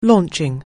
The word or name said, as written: launching